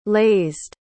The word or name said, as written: lazed